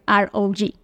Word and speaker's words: ROG: Entonces ahí de ahí nace ROG, que es como el rock, pero en, en, inglés. Normalmente así es como se debe pronunciar.